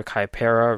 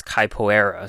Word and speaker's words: Capoeira: During the standby phase of this card is in defense, you can special summon a Dinowrestler, Capoeira, Raptor from your deck. You can use this effect once per turn. I said it wrong because I should know how to say it. It's Capoeira, so Capoeira, Raptor.